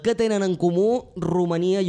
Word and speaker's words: Romania: Exacte, ho pronunciem bé, exacte, ho pronunciem bé, perquè com que ja sabeu que les nostres tòniques quan tornen àtones se pronuncien ús, és a dir, no hi ha cap pega. Romania, Hongria.